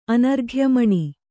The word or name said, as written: ಅನರ್ಘ್ಯಮಣಿ